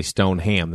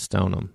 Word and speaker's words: Stoneham: And I grew up in Stoneham, but people will say Stoneham. They're like, oh, Stoneham. I'm like, no, it's Stoneham.